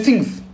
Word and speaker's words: Things: Não, e Rafael, você tá falando errado, amigo. É. é Stranger Things. Things. Things.